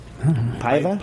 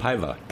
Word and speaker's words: Paiva: Pedro, how do you think you pronounce P-A-I-V-A? Portuguese. Paiva? – Paiva.